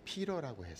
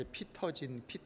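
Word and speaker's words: Peter: 제가 한국에 나갔더니 한국에서 제일 힘든 것 중에 하나가 이름을 갖다가 이제 제가 진재혁인데 영어로 이름이 피러라고 했어요. 피러라고 하니까 사실 미국에서는 그냥 피러진 이렇게 부르는데 한국에 갔더니 이름이 피러라고 발음 안 하고 피터라고 발음을 해요. 그러니까 제가 이제 피터진 그래서 집회를 갔더니 피터진 목사님 나오시겠습니다.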